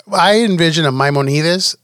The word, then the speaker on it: Maimonides: So there's a quote from Maimonides. Did I say that right? – Maimonides, we said sounds like Batida from like Terra Luna.